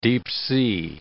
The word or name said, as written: deep-sea